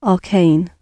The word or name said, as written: arcane